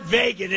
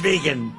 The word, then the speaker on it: vegan: It's not vegan, it's vegan. I don't know about that stuff. I'm not into it. And he said, it's vegan. Please pronounce that word properly.